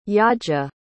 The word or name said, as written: Yaxha